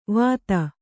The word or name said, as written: ವತ್ತಾ